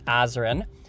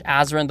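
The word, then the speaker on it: Azren: It is at Polyglot Azrin. That is spelled P-O-L-Y-G-L-O-T, A-Z or Z, depending on your country, R-E-N. You can also simply search Azrin the Language Nerd on whatever your favorite social network is.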